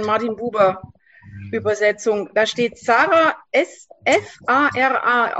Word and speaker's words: Sarah: Und Sarah wird im Hebräischen ausgesprochen Sarah, mit Doppel-S. Und Huber schreibt das gerne mit Doppel-S, damit ihr wisst, es ist ein scharfes Sarah.